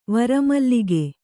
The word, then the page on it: ವರಮಲ್ಲಿಗೆ: vara mallige